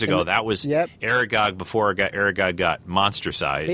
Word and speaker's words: Aragog: Aragog is Aragog,